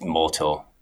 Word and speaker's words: Mortl: He's Mortal, but it's spelled Mortl.